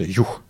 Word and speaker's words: юг: Их немного, и за пределами столиц это в первую очередь юг, да, именно вот так это произносится, юг.